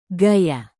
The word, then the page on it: gaya: Indonesian